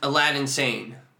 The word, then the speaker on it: Aladdin Sane: Aladdin Sane.